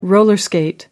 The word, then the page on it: roller skate: American English